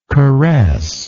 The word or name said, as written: caressant